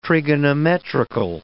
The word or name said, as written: trigonometrical